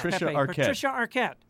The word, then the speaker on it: Patricia Arquette: Well done, Pepe. Somewhere Patricia Arquette has sensed that you have pronounced her name correctly.